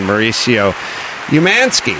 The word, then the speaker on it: Mauricio Umansky: And then Mauricio Umansky.